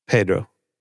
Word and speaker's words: Pedro: But Pedro Pascal, Pedro, that's how he pronounces his name.